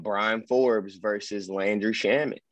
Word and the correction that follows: Bryn Forbes: I'm gonna need you to say his name right. His name is Bryn Forbes, sir. – Bryn. – I'll take Bryn Forbes.